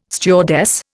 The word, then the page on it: stewardess: Indian